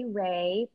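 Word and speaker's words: Wray: So you can reach me on Instagram at Lindsay Ray, PhD, LindsayWray. My last name has a silent W, PhD.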